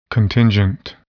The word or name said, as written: contingent